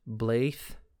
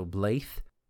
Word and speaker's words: Bladh: Bladh means wolf. It's pronounced Blaith. That DH is a hard TH sound, so Blaith. Kas, C-A-S, means battle. Delem, D-E-L-E-M, means leaf.